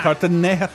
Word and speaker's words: Cartagena: Cartagena. – That's a G. You pronounce the G.